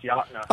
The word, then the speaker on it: Yocona: I try, but like I said, okay, so the Yocona River Roper, Yocona River, now that must be a place y'all like to go hang out and vacation at. – Well, so, yeah, it's actually – pronounce Yachtna. Oh, of course. Yachtna, right? Of course it is, because that's me. I can mispronounce my own name.